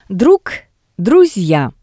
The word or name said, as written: DrugDrusya